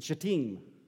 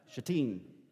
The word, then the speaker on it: Shittim: All right, now, so, and Israel abode in Shittim. And that's actually how you pronounce it. The emphasis is on the team. Shittim.